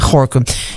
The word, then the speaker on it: Gorinchem: Ja, en ik struikel af en toe, want ik zie dan altijd Gorinchem staan. En dan denk ik, hoe spreek je dat ook alweer uit? Maar het is natuurlijk gewoon Gorinchem en niet anders.